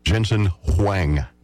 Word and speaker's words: Jensen Huang: Jensen Huang. – Jensen Huang.